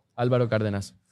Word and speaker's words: Álvaro Cárdenas: Álvaro Cárdenas.